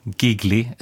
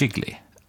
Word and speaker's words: jiggly: Vi ska prata om allt från giggly, utomlande så, eller är det jiggly? – Jiggly, men det uttalas på lite olika sätt i den här filmen.